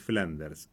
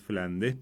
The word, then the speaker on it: Flandes: Flandes es, mejor dicho, no es Flanders, es Flandes, pero bueno, pronunciado así rapidito suena como a eso, ¿no?